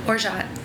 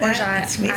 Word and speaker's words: orgeat: Orgeat. Oh. Orgeat. – No one's told me that. Orgeat.